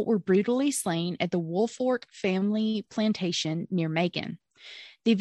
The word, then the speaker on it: Woolfolk: And I will say this too, before I continue, I'm pronouncing it correctly in Woolfork, but it is spelled Woolfolk, like wool from sheep and folk, like people, but you pronounce it Woolfork.